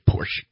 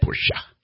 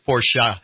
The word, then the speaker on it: Porsche: I know, everybody wants to say Porsche. It's not. It's Porsche. – It's Porsche.